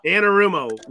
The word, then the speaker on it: Anarumo: Fucking Lou Amarillo. – Yes. – Amarillo? Yeah. Who the fuck I can't ever pronounce it. – Armadillo? Anarumo. – Anarumo. Lou Anarumo.